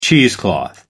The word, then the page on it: cheesecloth: American English